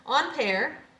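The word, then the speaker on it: Ampère: I was pronouncing that incorrectly, which is no surprise if you're a regular listener. On pair, André Marie, or André Marie on pair. And it is not spelled the way it's pronounced.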